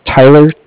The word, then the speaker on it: Tyler: My name is Tyler, T-Y-L-E-R. – Okay. – And I'm calling with Cardiff. It's spelled C-A-R-D-I-F-F, as in Frank.